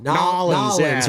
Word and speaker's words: New Orleans: It's like, see, we say like New Orleans or New Orleans, but the locals say what? – Nollins, right?